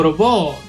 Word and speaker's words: Robot: No, allora l'aneddoto di Atlas UFO Robot, noi dovremmo dire in teoria Atlas UFO eh, Robot, almeno cioè robot è la pronuncia corretta, però diciamo la diciamo alla francese perché?